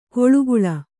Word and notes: ಕೊಳುಗುಳ: koḷuguḷa

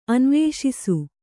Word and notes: ಅನ್ವೇಷಿಸು: anvēṣisu

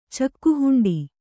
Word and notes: ಚಕ್ಕುಹುಂಡಿ: kannada

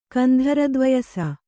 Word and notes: ಕಂಧರದ್ವಯಸ: Kannada

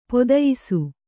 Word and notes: ಪದಯಿಸು: Kannada

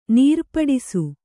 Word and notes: ನೀರ್ಪಡಿಸು: kannada

